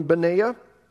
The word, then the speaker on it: Benaiah: Today we're going to look at a man named Benaiah. Some pronounce his name Benaiah.